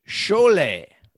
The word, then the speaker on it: schole: Shoals evolved from the Dutch root word schole. – Schole. – Pronouncing that correctly, meaning a troop or a crowd.